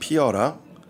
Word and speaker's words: Piora: And funny thing is, um, Piora in Korean, pronounced Piora, translates to blossom.